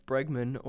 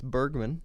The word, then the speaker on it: Bregman: Alex Bregman, or as MLB 9 Innings calls him, Alex Bergman.